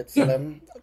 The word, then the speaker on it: B'Tselem: The Israeli human rights group B'Tselem says that, did I say that? Was that okay? – Yeah, B'Tselem? – that's Helen, yeah.